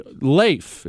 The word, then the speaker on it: Leif: His name is L- uh, pronounced with an A, not an E. Leif is his name.